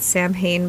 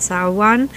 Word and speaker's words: Samhain: I have to say, like, for the people that don't know, the original Halloween, a lot of people think it's Samhain, but it's actually pronounced Samhain, and that was the original Harvest Festival, which was considered the new Halloween now.